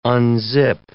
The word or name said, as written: unzip